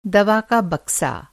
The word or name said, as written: बक्सा